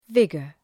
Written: vigor

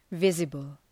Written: visible

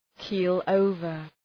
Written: keel over

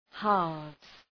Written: halves

